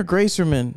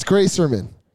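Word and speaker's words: Greyserman: Max Graersman. – Is it Graersman or Graserman? – It's Graserman.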